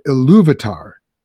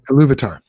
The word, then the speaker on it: Ilúvatar: But then when you get to talking about Ilúvatar, mm-hmm. did I pronounce that correct? – You did. Yep. – Ilúvatar, mm-hmm.